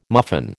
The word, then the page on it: muffin: American English